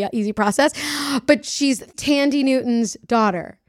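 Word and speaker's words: Thandie: Her name is spelled differently than it's pronounced. It's spelled like... – spelled Thandy. but it's Tandy.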